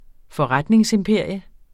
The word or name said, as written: FORRETNINGSIMPERIUM